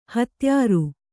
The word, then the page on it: ಹತ್ಯಾರ: Kannada